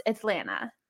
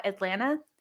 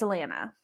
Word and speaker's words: Atlanta: So, and I was told by a friend that I used to have that it's not pronounced Atlanta. To like natives don't call it Atlanta. You drop the T. It's Atlanta.